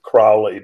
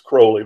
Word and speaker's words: Crowley: People sometimes pronounce it Crowley, but it's Crowley, rhymes with holy.